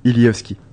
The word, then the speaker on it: Ilievski: Ilievski.